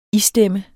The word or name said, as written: ISTEMMER